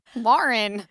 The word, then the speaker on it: Lauren: She'll say, I love you, Lauren. And I'm like, no, Lauren. So I've been trying to teach her how to say Lauren.